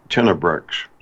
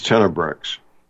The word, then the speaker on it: Tenebrex: The name of the company, I'll say it and spell it twice phonetically, Tenebrex. It's Tango, Echo, November, Echo, Bravo, Romeo, Alpha, Echo, X-Ray. I say again, the name of the company is Tenebrex.